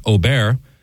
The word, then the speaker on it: Aubert: A-U-B-E-R-T. – But it's French, so it's something like Aubert.